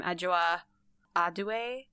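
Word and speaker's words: Adjoa Andoh: I think you pronounce her name Adjoa Adue. – That sounds right to me.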